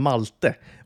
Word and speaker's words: Malte: Eh, eh, men på tal, om, på tal om Malta och på tal om Malte och på tal om Frankrike så heter ju då Malta på franska Malt. Alltså man uttalar det Malt, eller stavas mm. Malte.